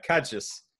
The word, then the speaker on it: Cairdeas: They make an annual expression, and courtesy of Deviant Alam, I now know how to pronounce it correctly in Gaelic, which is pronounced Cadjus. But it's spelled C-A-I-R-D-E-A-S.